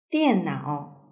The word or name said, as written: diannao